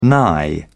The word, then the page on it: nigh: British English